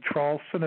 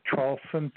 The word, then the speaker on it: Traulsen: My name is Hugh, H-U-G-H. The last name, Trawlson, is spelled T like Tom, R-A-U-L-S-E-N like Nancy. And there's a big website, if you just Google three words, my last name, Trawlson, T-R-A-U-L-S-E-N, the word and, A-N-D, and then Trump.